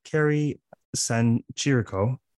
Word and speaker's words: Kerry San Chirico: More importantly, I have the pleasure today of speaking with Dr. Kerry San Chirico.